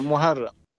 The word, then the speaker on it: Muharrem: Hur säger man hans namn nu? Murahem Demirok. – Demirok. Precis. – Uh, Muharrem uttalas.